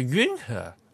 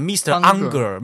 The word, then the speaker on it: Unger: 然后呢、那个荷兰语的那个 U 啊，就是有点像汉语拼的 U 啊，啊，所以是恩赫啊，不是就和。就很多说英语的人嘛，把这个 UNGER 嘛，就给他分，就念成 Mr. Unger， Mr. Unger，听起来好像愤怒先生一样。